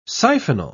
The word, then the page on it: siphonal: British English